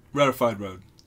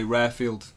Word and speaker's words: Rarefied Road: Rarefied Road. – Yeah, I didn't know if I was going to pronounce it. – No, most people say Rarefield. – Rarefield Road, Rarefied Road.